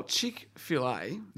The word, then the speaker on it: Chick-fil-A: Well, Chick-fil-A.